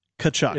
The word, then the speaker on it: Tkachuk: It's Tkachuk. – It's a silent T that I'm pronouncing.